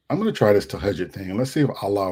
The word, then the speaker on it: Tahajjud: I called it Tajahoon. I didn't know how to pronounce it. So Tahajjud.